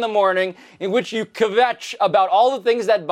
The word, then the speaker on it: kvetch: It's a really nice job you making kvetch into a two-syllable word. – Kvetch. – Like that. That's it.